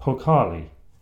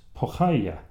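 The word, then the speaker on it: pokhaya (поехали): The correct term for the more general let's go is a word that's spelt, well, in Roman script anyway, P-O-E-K-H-A-L-I. So it sounds like pokhali, but from what I can make out, it's pronounced pokhaya.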